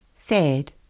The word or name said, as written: sad